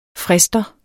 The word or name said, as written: frister